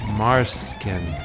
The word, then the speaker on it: Marsken: They have a record label together called Markson Records. You can find all of the material that they do on Bandcamp. No, I'm sorry, I said that wrong. Marsken. M-A-R-S-K-E-N. Marsken Records.